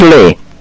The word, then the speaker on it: Lee: Richard Lee.